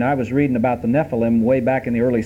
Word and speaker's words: Nephilim: The word for giants is Nephilim. N-E-P-H-I-L-I-M. Or if you want to pronounce it in Hebrew, Nephilim.